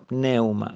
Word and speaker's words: pneuma: Es interesante que la palabra Espíritu en el original griego es la palabra pneuma. Y se escribe pneuma, con P al principio, ¿no? Pneuma.